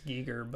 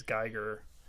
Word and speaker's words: Giger: Yeah, I think it's Giger, but I think it's so commonly pronounced as Geiger.